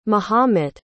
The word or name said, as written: mahomet